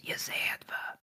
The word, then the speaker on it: Yezedva: Also, Sasley, you keep adding an extra syllable. It's just, and then she whispers, it's just, Yezedva. It just kind of rolls, Yezedva.